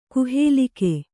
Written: ಗುಹಾಲೇಖ

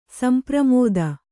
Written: ಸಂಪ್ರಮೋದ

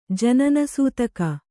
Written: ಜನನಸೂತಕ

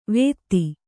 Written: ವೇತ್ತಿ

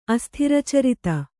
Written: ಅಸ್ಥಿರಚರಿತ

